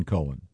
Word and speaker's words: Cohen: Leonard Cohen. – Cohen. – This that's confusing because not when it's spelled C-O-H-E-N. – Yeah. It's definitely Cohen.